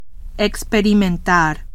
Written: experimentar

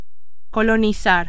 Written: colonizar

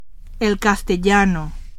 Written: castellano

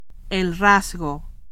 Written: rasgo

